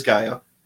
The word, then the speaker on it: Gaia: I've heard it said both ways, but it is Gaia.